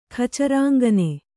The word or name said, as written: ಖಚರಾಂಗನೆ